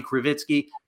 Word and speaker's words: Krivitsky: My handle is at Bobby Krivitsky. That's spelled K-R-I-V as in Victor, I-T.